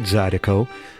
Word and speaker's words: Zydeco: Before Clifton Chenier claimed credit for the word Zydeco, the way it's spelled today, Z-Y-D-E-C-O, through his song Zydeco Zampasale, multiple spellings and pronunciations were used, including Zorico, Zodico, and, like Lightnin' says, Zolo Go.